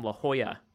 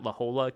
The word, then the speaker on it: La Jolla: Well, I mean, I got called out for it's Daniel from La Jolla, California, not La Jolla, California. Because I pronounce it phonetically, apparently.